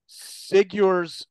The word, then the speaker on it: Sigur: His Substack is called Sigur's Substack. That's S-I-G-U-R-S. Did I pronounce that right? Sigur's? – Yeah, it's Sigur. Yeah, like it's Norse for victory.